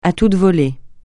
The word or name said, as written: à toute volée